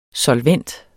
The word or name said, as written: solvent